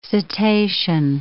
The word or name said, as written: cetacean